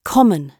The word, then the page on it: common: British English